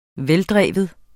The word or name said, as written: veldreven